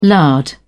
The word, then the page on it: lard: British English